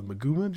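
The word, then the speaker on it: Maguuma: We're getting, just going to rattle down the list real quick, a new area, the jungle, the Maguma jungle.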